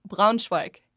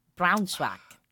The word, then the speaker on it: Braunschweig: It was near Braunschweig. – Braunschweig. – That's the one.